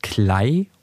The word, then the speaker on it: Kley: Ähm, Clay Ski kommt eigentlich daher, mein Nachname ist Clay und ich, da es K-L-E-Y geschrieben wird, wurde ich eigentlich mein Leben lang immer Clay genannt, weil die meisten das E halt als E-Y ausgesprochen haben, als A.